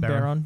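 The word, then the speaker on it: Barone: So the creator and developer Baron, is that how you pronounce his last name? – Baron, mm-hmm. yeah.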